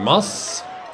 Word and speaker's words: Maß: Also die Maß. – Und das A wird sehr kurz und knapp ausgesprochen, so ähnlich wie in Fass, was ja auch wirklich nahe liegt.